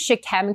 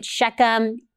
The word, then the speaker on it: Shechem: I know some people pronounce it Shechem, tomato, tomato today. Shechem, Shechem, whatever you want to say is fine by me. It's the same place.